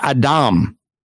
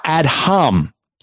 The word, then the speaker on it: Adam: That's because this is the actual word Adam, which is how you would pronounce it, not Adham.